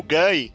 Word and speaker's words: Gay: O Gai se escreve gay. Ah, ah. – Correto, a correta grafia dele é G-A-Y.